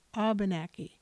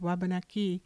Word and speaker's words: Wabanaki: The French, however, had no sound for W. They took the word Wabanaki and made it Abenaki. So you can tell someone's ancestral background by how they pronounce the word.